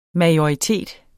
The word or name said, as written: MAJORITETS